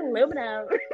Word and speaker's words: aluminum: My mom would say aluminium, because that's how the British say it. And I'd be like, Mom, it's aluminum.